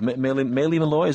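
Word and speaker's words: Maile Meloy: Maile Malloy. Is that the right pronunciation, Mark? – I believe so.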